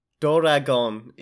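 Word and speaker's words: Doragon (ドラゴン): And underneath it says Doragon. It's Doragon. Not even a real dragon. Doragon. – That's... – Was this copyrighted? Was the word dragon copyrighted? – No, that's how Doragon is the Japanese pronunciation of that You can't word.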